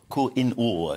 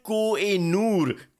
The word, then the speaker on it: Koh-i-Noor: Koh-i-Noor, I think, is the correct pronunciation. – It's the Koh-i-Noor.